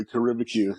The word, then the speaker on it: Caribeque: Am I even pronouncing it right? – Yeah, you are actually, Caribeque. Yeah, so it's a hyphen for like Caribbean barbecue.